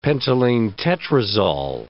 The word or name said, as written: pentylenetetrazol